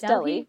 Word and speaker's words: Delhi: Just Delhi, yeah. – Delhi. I know, but sometimes I'm like, is it actually Delhi?